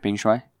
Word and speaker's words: Peng Shuai: So essentially, Peng Shuai. That's how I pronounce it, right? Peng Shuai. – Yeah, that's right.